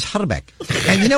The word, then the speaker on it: Hrbek: I speak a little bit of Czech, and the actual correct pronunciation of his name is Herbeck. You got to roll the I. Herbeck.